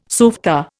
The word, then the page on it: softa: Indian